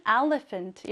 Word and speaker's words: elephant: 이렇게 ph 발음을 f처럼 발음을 해서 I see an elephant 이렇게 발음해 주시고 나는 코끼리가 보여 라는 뜻이 됩니다.